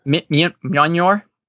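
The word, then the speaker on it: Mjölnir: You got Thor and Beta Ray Bill fighting over, and I can never say the darn hammer right, but Mjolnir, how you say it? – Mjolnir? – Yeah, thank you.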